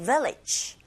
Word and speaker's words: village: Village 하실 때 여러분 강세가 뒷부분에 없잖아요. 앞부분에 있잖아요. 그리고 V 발음하실 때 윗니로 아랫입술을 살짝만 밖에서 안으로 긁어주시면 됩니다. Village가 아니고 village. Village. 강세를 앞에 정확하게 주시면 끝발음 G가 어색하게 들리지 않게 됩니다.